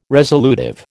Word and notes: resolutive: American English

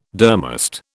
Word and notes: durmast: British English

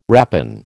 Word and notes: rapine: American English

